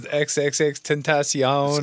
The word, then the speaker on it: XXXTentacion: Also, you know, I mean, it's kind of like similar to Xtentation, just these troubled kids that come. – Xtentation. – That's how you pronounce it, right? – I don't even know. – Yeah, Xtentation. XXXTentation. – It was XXXTentacion.